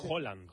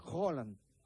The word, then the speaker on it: Haaland: Es Haaland. – Haaland. Como si dijeras un queso, ¿no? – ¿Sabes qué pasa el martes?